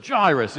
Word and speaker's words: Jairus: That wasn't quite how they pronounced it in the American version, but Jairus is how we would pronounce it.